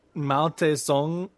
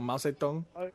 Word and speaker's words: Mao Zedong: Is that like Mao Tse Tung or something? Zedong? I said it wrong. Mao Zedong?